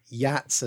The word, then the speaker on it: Geats: There's also been attempts to connect the name Jutes with the Geats, or Yats as it would be pronounced in Old English, the people mentioned in Beowulf, right.